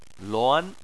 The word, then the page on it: luan: 2